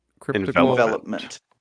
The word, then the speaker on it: Cryptical Envelopment: How do you say that? – Cryptical Envelopment. – And Envelopment. Cryptical Envelopment.